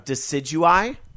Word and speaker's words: Decidueye: Decidueye? – Yeah, that's good enough.